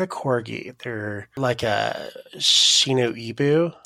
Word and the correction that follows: Shiba Inu: Is that how you say it? – Shiba Inu.